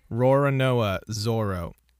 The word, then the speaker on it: Roronoa Zoro: Roronoa Zoro.